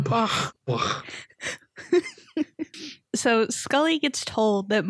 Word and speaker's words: Bach: Every every time we listen to like the classical station and they're like by Bach, I like always make fun of them for it, but it is technically the correct pronunciation.